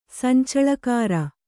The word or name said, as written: ಸಂಚಳಖಾರ